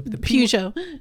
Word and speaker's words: Peugeot: The Puget, I call it. The Peugeot. – The T is silent. Yeah.